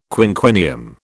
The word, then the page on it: quinquennium: British English